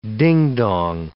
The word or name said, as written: ding-dong